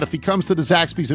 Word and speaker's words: Zaxby's: Several weeks ago, Michael said he was interested in trying a Zaxby's salad. That's how they say it, with a Z.